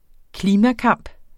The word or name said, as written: klimakamp